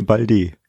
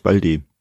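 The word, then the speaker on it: Baldé: Eigentlich heißt es doch Balde, oder? Nee, nee, nee, no? nee, nee, Balde. Echt, ja? Ja, man, man schreibt zwar das E-Axon Tigü, aber äh, eigentlich Balde.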